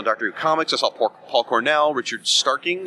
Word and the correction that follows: Starkings: Is that how you say his name? – Richard Starkings. – Starkings, yep.